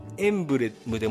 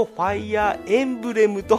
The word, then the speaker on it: ファイアーエムブレム: えー、口頭の発音はエンブレムでもいいんですけど公式関係ではむしろファイヤーエンブレムと発音されていることが圧倒的に多いもうわやわやちなみにこの間違ったパターンの表記も商標登録済みらしい。マジで。